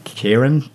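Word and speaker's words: Ciaran: I forgot his name, but I think you pronounce it Kieran. Kieran. It's C-I-A-R-A-N.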